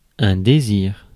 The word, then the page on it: désir: /de.ziʁ/; France